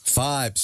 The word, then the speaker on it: Fybe: Am I saying this right? Fiber? – Five. – Sorry. – F-Y-B-E. – F-Y-B-E. Five.